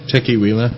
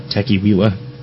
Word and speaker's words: Teqiwila: How do you pronounce T-E-Q-I-W-I-L-A? – Tequiwila. – Tequiwila. Oh, because it's got it's kiwi. – Yeah. – It's tequila with the key made into kiwi.